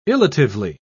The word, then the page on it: illatively: British English